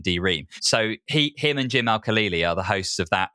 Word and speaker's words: Al-Khalili: So, World According to Physics, Jim Al-Kahali. – So I'm pretty sure that I'm right in saying you're butchering his name, and it's Jim Al-Khalili from Radio 4, okay.